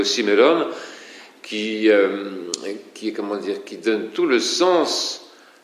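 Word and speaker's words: simeron (σήμερον): Or ce mot aujourd'hui, qui est simeron en grec, eh bien, il est accentué sur la première syllabe, simeron, mais ce simeron, donc pour l'accentuer encore plus, engendre des mélodies appropriées dans le chant byzantin.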